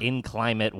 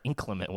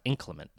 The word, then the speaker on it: inclement: It's not inclimate weather, inclement weather. – It is. It's inclimate weather. – Yeah, I think that's the origin of the word, but it's pronounced inclement.